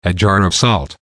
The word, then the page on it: a jar of salt: American English